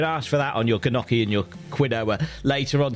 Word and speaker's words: gnocchi: Gnocchi. That G is silent, isn't it? It's not gnocchi.